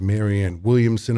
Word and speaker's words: Marianne Williamson: And we are very excited to have Marianne Williamson.